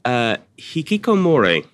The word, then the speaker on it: hikikomori: I wanted to ask, if I may, just looking at my notes, you talked about hikikomori, with apologies for pronunciation.